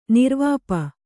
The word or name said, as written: ನಿರ್ವಾಪ